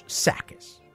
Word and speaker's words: Tsakis: Just look for Chris Sakis, my actual last name, T-S-A-K-I-S. Yes, the T is silent.